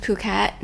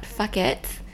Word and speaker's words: Phuket: And yes, it's pronounced Phuket, not fuck it.